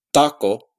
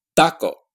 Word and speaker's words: taco: So it's not pronounced taco, it's pronounced taco. With the tongue tip at the teeth.